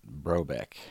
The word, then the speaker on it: Brotbeck: You pronounce it like the T is silent, Brobeck. – Brobeck, okay. – It's technically Brotbeck. It's German. I'm from Swiss heritage. It means bread baker, Brotbeck.